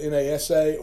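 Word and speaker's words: Nasa (נשא): The first is, is uh, or, or it's actually spelled N A S A or N A C A H. It's pronounced Nasa, like N A W S A W.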